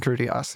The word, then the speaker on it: Critias: Is it Timaeus and Critias? I don't know. – Timaeus and Critias, yeah. – Yeah, great. That's right.